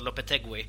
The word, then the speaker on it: Lopetegui: Jag pratade med en spanjor här om just hur man ska uttala hans namn och han sa Lopetegui. Så vi kanske ska börja med det istället för Lopetegui.